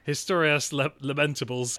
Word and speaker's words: Historias Lamentables: That, for some reason, I put a French pronunciation on that. That is absolutely incorrect. It's a Spanish film. Sad stories. Historias Lamentables.